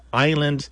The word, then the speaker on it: island: When you see something curious, like a letter not being pronounced, it is a really strong clue, Allie, that there's a story there. And in this case, it tells us that the island came to us from French. And so we have this little note that says, there's a word history here. Island has a little bit of French history. The S is not pronounced in French.